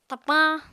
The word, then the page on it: tapañ: Apatani